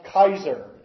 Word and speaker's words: Caesar: Now, Caesar is a Latin word. It's literally pronounced Kaiser in Latin. I won't let it go to my head. But Kaiser, the Germans took as their term for emperor from the Latin, from the Romans.